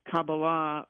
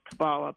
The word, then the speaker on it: Kabbalah: the Kabbalah or Kabbalah, people say it, depends on where you are in the world, how you pronounce it.